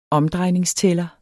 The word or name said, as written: omdrejningstæller